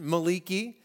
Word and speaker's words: Malachi: Join me in Malachi, if you prefer the Hebrew pronunciation, Maliki.